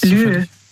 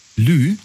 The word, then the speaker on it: lieu: Lieu. Lieu. – Lieu? – Also Lieu, das schreibt man, also L-I-E und dann U.